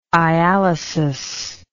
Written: ialysus

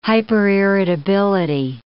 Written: hyperirritability

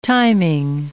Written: timing

